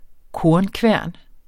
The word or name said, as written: kornkværn